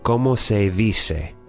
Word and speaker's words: cómo se dice: From the dialogue, we have cómo se dice. – Yes. – All right. And that D is actually with your tongue between your teeth.